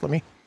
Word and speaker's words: Lamy: Lamy, as Santa Fans call it. They call it Lamy, but it's French. It's pronounced Lamy.